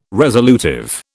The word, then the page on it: resolutive: British English